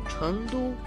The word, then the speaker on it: 成都: Чхэн читается во втором тоне, а Ту – в первом тоне. – Повторяйте за мной. Чэнду.